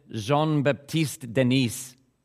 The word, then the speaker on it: Jean-Baptiste Denis: French physician Jean-Baptiste Denis.